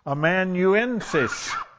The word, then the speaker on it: amanuensis: amanuensis.